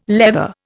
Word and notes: leather: Indian

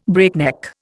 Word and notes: breakneck: Indian

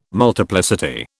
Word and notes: multiplicity: British English

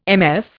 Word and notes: MF: Indian

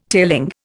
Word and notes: tailing: Indian

